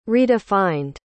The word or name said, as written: redefined